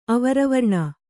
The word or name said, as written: ಅವರವರ್ಣ